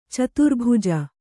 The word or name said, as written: ಚತುರ್ಭುಜ